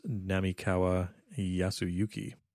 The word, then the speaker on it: Namikawa Yasuyuki: We spent the entire episode talking about Namikawa Yasuyuki, and I ended up mispronouncing his name early on in the show, which meant that John mispronounced his name through the show as well. The pronunciation that we used in the last episode is incorrect. It is Namikawa Yasuyuki.